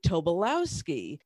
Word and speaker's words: Tobolowsky: No, I thought it was Tobolowsky. It's actually Tobolowsky, T-O-B-O-L-O-W-S-K-Y.